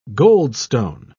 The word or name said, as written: goldstone